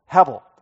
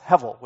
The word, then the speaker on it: hevel: That is the word hebel, or it probably is better pronounced hevel with a V. That B generally makes the V sound.